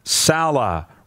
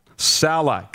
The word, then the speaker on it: Saleh: From DG in Saskatoon, he says, hey, Roddy, number one, it's Robert Saleh. Rhymes with Lady Gaga. Saleh.